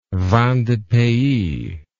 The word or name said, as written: vin de pays